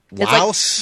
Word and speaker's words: Lao: Laos? – Yes. – Oh, she's Laotian. – Yeah. – I think is how you say that, right? – Lao. – Okay. Oh, you're Lao.